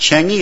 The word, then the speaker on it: Chang'e: it's pronounced Chang'e, it's after a goddess of theirs, the first unmanned landing on the far or dark side of the moon.